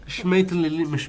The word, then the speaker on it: Schmetterling: Reaper Schmetterling.